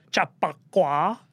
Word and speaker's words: Chappaqua: Chappaqua.